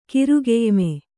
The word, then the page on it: ಕಿರುಗೆಯ್ಮೆ: kirugeyme